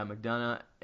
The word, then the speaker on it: McDonough: McDonough? – Yeah, McDonough.